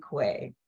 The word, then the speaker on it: Quaye: But the real story is my mother stole the name from my aunt in order that my middle name rhymes with my sister's. So there was Tommy May, Alma Faye, and unfortunately, I ended up with Dorothy Quaye.